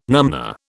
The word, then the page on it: numnah: British English